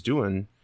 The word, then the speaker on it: Doohan: Some people have been on the verge of suicide, and there's actually a, a great story of James Doohan. Uh, I think did I say that right? Yeah.